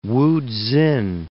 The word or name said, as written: Wutsin